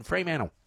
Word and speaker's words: Fremantle: There is an L in the name, like there is a T in Fremantle.